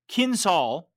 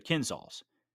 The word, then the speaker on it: Kinzhal: We see that this time as many as six Kinzhal," I believe that's how you pronounce it, that's what they call these hypersonic missiles, that's K-I-N-Z-H-A-L, missiles were used.